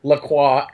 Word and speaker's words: La Croix: The Publix had La Croix. Yes, that is how you say it.